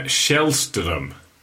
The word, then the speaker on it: Källström: Kim Källström.